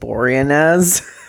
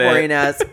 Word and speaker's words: Boreanaz: You know, I pronounce it Boreanaz. It is pronounced Boreanaz.